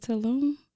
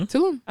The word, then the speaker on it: Tulum: Is it Tulum? – Mm-hmm. – Tulum. – Okay, I got it right.